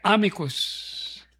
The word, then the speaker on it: amicus: Yun daw po Latin words, the accent is always on the first syllable. So we say amicus.